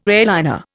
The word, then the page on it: airliner: Indian